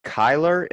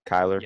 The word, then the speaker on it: Kyler: Boom, I'm here with Kyler. Am I saying that properly? Kyler? – Yeah.